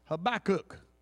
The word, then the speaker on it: Habakkuk: I know some of you look around and say, he ain't saying it right. That's exactly how it should be enunciated. It's H-B-A-K-K-U-K. Amen. Habakkuk. It's not a Jewish holiday. It's not a health food.